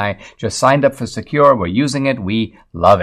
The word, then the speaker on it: Sekur: I want to tell you about Secure. It's spelled S-E-K-U-R.